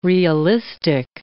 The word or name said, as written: realistic